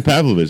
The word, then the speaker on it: Pavlovich: Sasha Pavlovich. Let's throw him in there. – You actually said his name right.